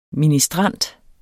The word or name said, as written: ministrant